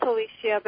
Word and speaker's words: Alicia: Well, it's spelled Alicia, but you can call, we all pronounce it Alicia.